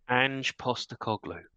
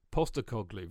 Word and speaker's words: Postecoglou: Right, who would like to pronounce his name for me? Because I haven't got a fucking Scooby. – Ange Postecoglou. – Postecoglou. Very good.